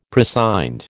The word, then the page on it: prescind: American English